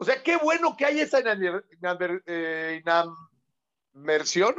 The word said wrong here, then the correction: animadversión: o cómo se dice, inadmer... Ani, anima, animadversión. – Animadversión, perdón, perdón, perdón.